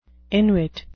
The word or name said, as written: inwit